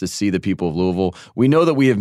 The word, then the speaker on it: Louisville: We said that right. Louisville, Kentucky.